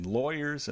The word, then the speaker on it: lawyers: This one can be tricky because of the vowels that are used. Even though you see L-A-W, think law, lawyers actually has more of an oi sound inside.